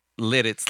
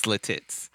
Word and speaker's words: Lititz: Hey, I need to come back around and say, Bryce, look, I was just looking for some company in these to mispronounce that. I called Lititz Lititz.